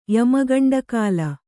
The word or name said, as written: ಯಮಗಂಡಕಾಲ